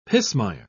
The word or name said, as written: pismire